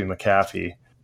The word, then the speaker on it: McAfee: there's no A before the C, so it must be McAfee. But it's okay. – You add a non-silent A in there, huh? – Something like that.